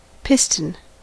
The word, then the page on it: piston: American English